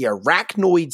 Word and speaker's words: arachnoid: He cited the arachnoid cyst on his brain. Did I say that right? Arachnoid? – I think you got that right, yeah.